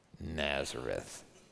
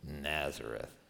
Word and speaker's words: Nazareth: That's how you pronounce it. Nazareth. It's like the facial, like, Nazareth.